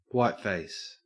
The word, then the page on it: whiteface: Australian English